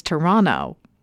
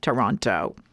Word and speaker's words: Toronto: All my years, because I just found out the official way to pronounce it like a local is to make the second T silent. It's Toronto, not Toronto.